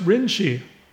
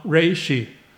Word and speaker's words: Rinshi: this is pronounced Rinshi, not Reishi, at Senyuji.